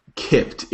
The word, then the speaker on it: Kippt: Kipped.